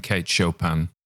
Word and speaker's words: Kate Chopin: Notice how I looked up how to pronounce Kate Chopin on the internet, Kate Chopin.